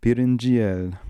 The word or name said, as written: Pirinjiel